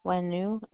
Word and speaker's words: Hoenou: Michelle Wenu, it's spelled H-O-E-N-O-U.